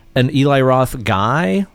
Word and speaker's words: Roth: Is that how I say that Roth. – Right? Am I pronouncing it right? – Uh-huh. Eli Roth.